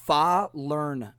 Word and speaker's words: falernum: Uh, I was looking up some of the pronunciation guides because I have always said falernum, right? But apparently, uh, the Barbadians pronounce it falernum, falernum, and uh, that has something to do with the um, uh, accent in Barbados and how they actually pronounce it um, with their kind of Caribbean accent. There's a, also apparently a, a fun joke uh, of the origins of this drink.